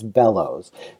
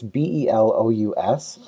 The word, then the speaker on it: Belous: Is Belous. Yeah, it's Belous. So it's Belous, but I like to tell people that pretend there's an extra L and a W in there, and that's easier to think about.